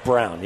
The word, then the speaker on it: Braun: It's spelled Braun, but I believe his name's pronounced Brown.